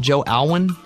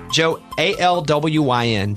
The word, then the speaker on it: Alwyn: Is that how you say his name? Joe Alwyn? Joe, A-L-W-Y-N.